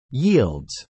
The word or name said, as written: yields